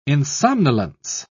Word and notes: insomnolence: British English

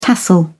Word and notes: tassel: British English

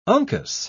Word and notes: uncus: British English

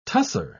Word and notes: tussah: British English

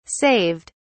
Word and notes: saved: British English

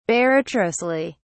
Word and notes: barratrously: British English

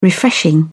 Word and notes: refreshing: British English

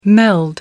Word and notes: meld: American English

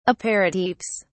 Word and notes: aperitifs: British English

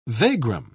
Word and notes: vagrom: British English

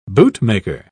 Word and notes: bootmaker: British English

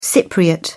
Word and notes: cypriot: English; British English

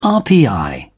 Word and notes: rpi: British English

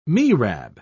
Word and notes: mihrab: British English

